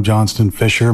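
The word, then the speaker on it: Johnston-Fisher: My name is Staff Sergeant Retired Shane Adam Johnston Fisher.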